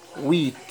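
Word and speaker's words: huit: ثمانية، ويت، الأش لا تنطق، ويت، تسعة، نف، عشرة، ديس، حداشر، أونز، اتناشر، دوز، ان، دو، تغوا، كاتر، سانك، سيس، ست، ويت، نوف، ديس.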